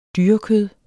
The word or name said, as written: dyrekød